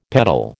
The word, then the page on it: peddle: American English